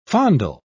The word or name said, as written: fondle